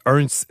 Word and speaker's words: Hernst: The Ernst Laurel Show donc, Ernst, vous savez peut-être pas comment l'écrire, H-E-R-N-S-T, La Roche, euh, comme ça se prononce.